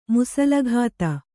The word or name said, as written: ಮುಸಲಘಾತ